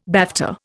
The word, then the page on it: BAFTA: Indian